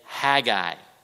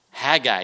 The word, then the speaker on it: Haggai: He said, Levi, say Haggai. Haggai. And he was able to do it. Haggai. He got it. You know, he's not even 2 years old yet. Haggai is this little prophet in the Old Testament that describes people who did not have the focal point in place.